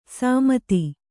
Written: ಸಮತಿ

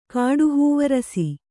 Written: ಕಾಡುಹೂವರಸಿ

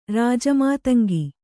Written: ರಾಜಮಾತಂಗಿ

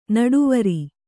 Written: ನಿಟ್ಟುವರಿ

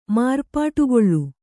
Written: ಮಾರ್ಪಾಟುಗೊಳ್ಳು

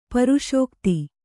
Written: ಪ್ರಸಕ್ತಿ

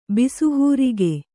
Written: ಬಿಸುಹೂರಿಗೆ

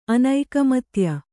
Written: ಅನೈಕಮತ್ಯ

